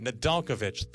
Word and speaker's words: Nedeljkovic: Nedeljkovic.